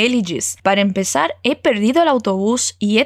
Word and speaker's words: empezar: Mais uma vez, e atenção ao som do Z, como eles pronunciam na Espanha, com esse sotaque bem espanhol. Vai lá. Para empezar.